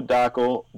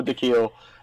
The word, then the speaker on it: Dakhil: You can follow me on Twitter, Mo Dakhil. Wow. I literally had a conversation with Brian before this started about the pronunciation of my last name, and I just screwed it up. You can follow me on Twitter at Mo Dakhil, M-O-D-A-K-H-I-L, _NBA.